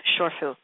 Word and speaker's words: shifu: He is actually considered, and I'm doing the Cantonese a translation of this particular word, he is considered a shorfu, or excuse me, a Mandarin pronunciation of this, shorfu, S-H-I-F-U.